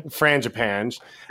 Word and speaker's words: Frangipane: Frangipane.